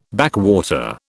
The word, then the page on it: backwater: British English